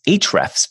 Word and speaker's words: Ahrefs: It's pronounced Ahrefs, but there's an A in the URL.